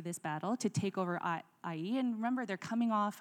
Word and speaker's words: Ai: And then they went on to their next battle, which is to conquer the city of Ai. It's pronounced Ai, I believe.